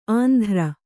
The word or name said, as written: ಆಂಧ್ರ